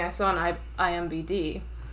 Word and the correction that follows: IMDb: which is... – IMDb. – Yeah, did I say it wrong? – IMBD.